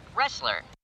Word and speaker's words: wrestler: Wrestler? It sounds like the letter R. – The word has the letter R, but it has a silent letter before it.